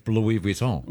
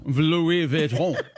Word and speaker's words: Louis Vuitton: You don't say Louis Vuitton either, do you? – Because it's Louis Vuitton. – Louis Vuitton. – I know. – I say it like Louisville.